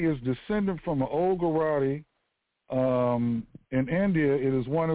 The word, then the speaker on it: Gujarati: Out of that, 70% of them are owned by Gujaratis, and most of them have the surname of Patel, P-A-T-E-L, or Amin, A-M-I-N. All right? Now, because they mentioned something very important, Gujarati. All right, and that's spelled G-U-J-A-R-A-T-I. Once again, Gujarati, G-U-J-A-R-A-T-I, Gujarati.